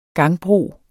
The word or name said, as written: GANGBROS